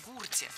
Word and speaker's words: гурте: Ведущий был прав. Если мы имеем в виду часть монеты, то «на гурте». А если бы мы говорили о стаде животных, то уже ударение должно быть на окончании. «Гурта», «гурте». Вот вам и омограф в действии.